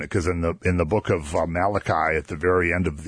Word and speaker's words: Malachi: Because, you know, the prophecies at the very end of the Old Testament in the book of Malachi, that's not Malachi, it's Malachi.